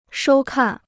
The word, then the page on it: 收看: shōu kàn